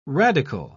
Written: radical

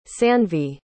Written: sanvi

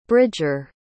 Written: bridger

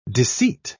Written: disseat

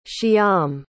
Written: shyam

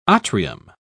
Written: atrium

